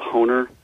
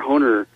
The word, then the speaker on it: Hoener: Well, again, I mentioned the work of Dr. Harold Honer. His last name is spelled H-O-E-N-E-R, and I think he did his doctoral dissertation at Dallas Seminary on chronological aspects of the life of Christ.